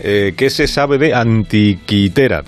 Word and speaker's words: Anticitera: Eh, y bueno, el, oyente está hablando de, una, de un dispositivo, un mecanismo, que se llama máquina de anticitera o de antiquitera. Antiquitera es, un, es una pronunciación mejor, más parecida al, original, que es un mecanismo muy antiguo y además creo que Cancho nos ha explicado dónde se encontró y cositas sobre él.